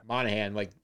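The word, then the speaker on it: Monaghan: How do you pronounce his last name? Monahan, like Dominic Monahan from Lost.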